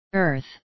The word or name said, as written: earth